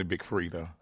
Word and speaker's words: Freedia: I have to ask you, Frida is spelled F-R-E-E-D-I-A, so it looks like Freedia. – Right. The I is silent, and it's close to my name. My name is Freddie, so we kind of kept close to my name, the spelling and the pronunciation a little bit. So the I is silent, but it's definitely Big Frida.